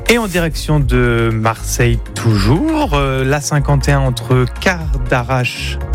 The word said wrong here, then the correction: Cadarache: Je le prononce bien? – Non. – Cadarache? – Mieux.